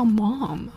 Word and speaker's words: ma'am: Mom It sounds so strange 就让我听起来感觉好奇怪妈妈因为我习惯叫就是 Mom 和 Mommy 然后北美就叫 Mommy 和 mom 然后我听到我朋友就管他妈妈叫 mom 就这样喊 mom 我就觉得 mom 我一直说 mom 怎么这边人们叫 mom 好奇怪我很不适应但是他的确是正确发音的两个 ma'am 就不对了 ma'am 也有 ma'am 绝对不是管你叫妈 ma'am 是 madam 的缩写